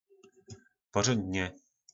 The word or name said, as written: porondne